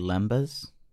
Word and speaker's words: lembas: They eat lombus bread, and they're like, lombus? I'm good for four. Months. – My guy, I think it's pronounced lambus bread. – Lambus bread. – It's probably actually lembas.